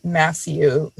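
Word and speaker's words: Mathieu: It's by this guy named W.A. Matthew, spelled like the French way. – Okay. – M-A-T-H-I-E-U, I think.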